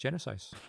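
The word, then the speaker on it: Genisys: It has a Y in it, so it's Genesis.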